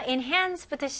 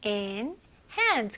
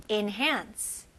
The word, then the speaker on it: enhance: 네, 첫 번째 단어 enhance부터 시작할게요. 네, 발음은 enhance, enhance. 그래서 e로 하지만 그냥 안으로 할때 in 그 발음으로 하셔도 돼요. enhance.